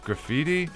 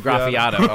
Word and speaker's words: Graffiato: I was struggling to pronounce it as well. But I was like, graffiti? Graffito? Yeah. – Big shouts out to Graffiato.